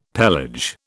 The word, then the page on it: pelage: British English